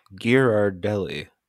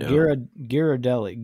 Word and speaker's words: Ghirardelli: And it's Ghirardelli. I have never pronounced that second R. That's new. – Ghirardelli. Ghirardelli. – Yeah, but it's G-H-I-R-A-R.